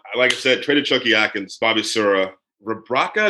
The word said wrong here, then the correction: Rebraca: Is that how you pronounce his last name? Zeljko, Rebraca.